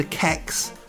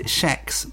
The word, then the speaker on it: Kex: Cloetta is a well-known maker of Swedish chocolate, including the Kex, or is it Chex, bar? There is no agreement on pronunciation. I prefer Chex to Kex because being a British northerner, Kex makes me think of underwear.